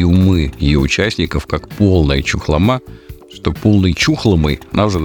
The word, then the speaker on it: Чухлома: Кстати, я с удивлением узнал, что местные жители делают ударение на букву «У». То есть она э, чухлома, а не чухлома, как привыкли мы.